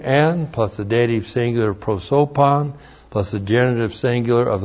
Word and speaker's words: prosopon: We have the preposition and, which is in, and then prosopon. P-R-O-S, long O-P-O-N. Prosopon.